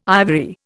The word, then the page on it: ivory: Indian